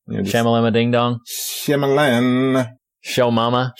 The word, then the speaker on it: Shyamalan: It is Shyamalan. I've been saying it wrong. I always pronounce the little Y in it, the Shyamalan. I always just put that little thing, but no, it's Shyamalan.